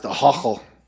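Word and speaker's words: Ahoghill: And I found out early in the recording process, there's an Irish place name, and it's A-H-O-G-H-I-L-L, and it's pronounced a hochel.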